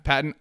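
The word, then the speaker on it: Paton: NFL Network's James Palmer noted on Thursday that Denver Broncos general manager George Patton, I think people pronounce it Payton, but Patton sounds so much cooler, noted that they have not, the Denver Broncos, made any calls about moving up in the NFL draft.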